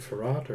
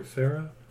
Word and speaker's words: Farrat: F-A-R-R-A-T. – Oh, Dr. Farrat or Farrah? – Farrat. – Farrat. Okay, sorry. I thought there was an H at the end.